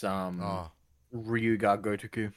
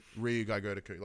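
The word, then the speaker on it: Ryu Ga Gotoku: Something Gudakai or no. It's Ryuga Gotoku. – Ryuga Gotoku.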